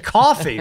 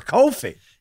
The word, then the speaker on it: Kofi: Everyone is very excited to say Cockburn when it's Coburn, but you say Coffee for Kofi.